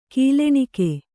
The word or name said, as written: ಗೊಲ್ಲಣಿಗೆ